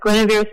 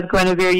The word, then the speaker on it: Guenevere: If you forget my name as Guenevere, you can just say, give me a beer, because it rhymes with Guenevere.